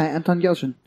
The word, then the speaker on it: Anton Yelchin: That's how you say it? – Yeah, Anton Yelchin.